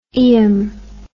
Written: ium

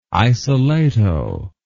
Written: isolato

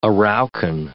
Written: Araucan

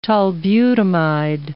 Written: tolbutamide

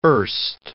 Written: erst

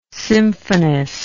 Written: symphonist

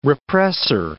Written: repressor

